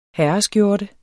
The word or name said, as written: herreskjorte